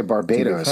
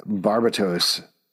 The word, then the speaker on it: Barbatos: thought you were going to say Barbados or something like that. Barbatos is actually how you pronounce it.